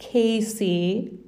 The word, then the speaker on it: Kayse: And my name is pronounced Kayse. I'm not joking.